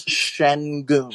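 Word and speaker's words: Şengün: I believe the correct pronunciation, this is not necessarily a fully correct, it's Sengun. – Sengun, like, okay. – There's an absent H in there, and then the U, it has an umlaut on it as well, so that triggers an U sound, so it's Sengun.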